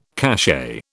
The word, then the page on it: cachet: British English